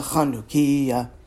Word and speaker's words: Chanukiah: But instead, we should be singing, O Hanukkah, O Hanukkah, come light the Chanukiah, with the emphasis on the first syllable, so the song still works.